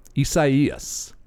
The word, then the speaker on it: Isaias: And the actual, if you're interested, the name of the store is Isaias. Isaias. – Yeah. – Is how you pronounce the storm.